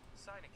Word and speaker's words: sinecure: Eh, how do you pronounce that? I'm sorry. Sinecure. That's it.